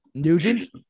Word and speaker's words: Nugent: Is it Nugent? I know how to say his name. Nugent? – Yep.